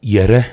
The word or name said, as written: Yere